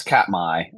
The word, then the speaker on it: Katmai: Yeah, it's Katmai.